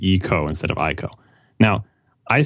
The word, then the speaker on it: Ico: And the first character is pronounced like the letter E, like E. And the second character is pronounced Ko. So it should be Ico, not Ico.